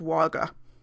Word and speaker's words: Wagga: And it turns out, if I remember correctly, they did say they are pronounced Wagga. However, I'm not saying it with an appropriate Australian accent, for which I apologize, but it is pronounced Wagga.